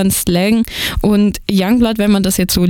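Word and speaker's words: Yungblud: Aber mit dem Doncaster-Slang wird Youngblood ausgesprochen,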